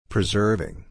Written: preserving

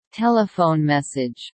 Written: telephone message

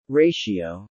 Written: ratio

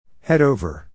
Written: head over

